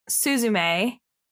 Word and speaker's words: Suzume: It's called Suzume. That's how it's pronounced.